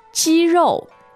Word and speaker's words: 肌肉: Let's choose 肌肉. 肌肉. – 肌肉. Tell us about this. – First and fourth tone.